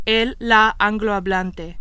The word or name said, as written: angloparlante